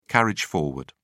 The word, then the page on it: carriage forward: British English